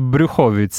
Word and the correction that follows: Brzuchowic: Z, brz- z Brzuchowic, tak.